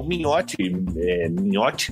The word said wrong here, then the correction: Mingote: como é que fala? Minhote, né? Mingote. Mingote.